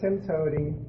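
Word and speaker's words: TIMTOWTDI: It's pronounced TIMTODY, and it really means there's more than one way to do it.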